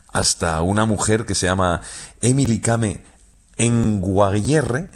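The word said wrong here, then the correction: Kngwarreye: lo he dicho mal. – Emily Kame. – Con Guarreye.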